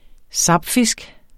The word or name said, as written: sapfisk